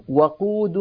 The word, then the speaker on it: وقود: فانتبهوا بارك الله فيكم إلى نطق هذه الكلمة وقولوا وأولئك هم وقود النار ونقيس عليها بقية الأمثلة المماثلة لها في كل القرآن الكريم وصلى الله على سيدنا محمد.